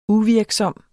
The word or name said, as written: uvirksom